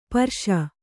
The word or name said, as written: ಪರ್ಷ